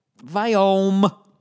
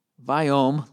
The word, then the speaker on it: Viome: Viome. The Vi is pronounced Vi, like Steve Vi. Viome, like biome.